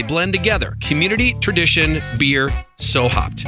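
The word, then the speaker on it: Sew Hopd: And they even spell their name in a cool way, like it's So Hopped, the beer, right? Well, they spell it SEWHOPD. So why spell it like I'm getting ready to sew up a seam?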